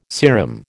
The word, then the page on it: serum: American English